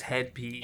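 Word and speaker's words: Hed PE: It's in parentheses, H-E-D, head, and then P-E. – I think it's just head P.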